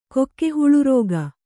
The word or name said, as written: ಕೊಕ್ಕೆಹುಳುರೋಗ